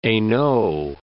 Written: Hainaut

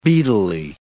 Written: beadily

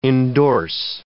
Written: indorse

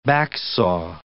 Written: backsaw

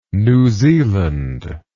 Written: New Zealand

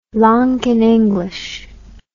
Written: lankan english

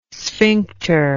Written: sphincter

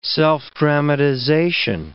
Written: self-dramatization

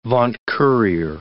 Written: vaunt-courier

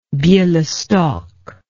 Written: belostok

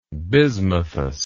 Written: bismuthous

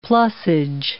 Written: plussage

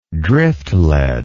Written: driftlead

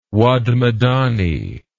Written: Wad Medani

